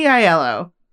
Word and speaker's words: Aiello: I was like, Danny Aiello or Aiello? – Aiello? Danny Aiello.